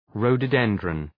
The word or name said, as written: rhododendron